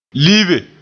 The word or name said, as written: libe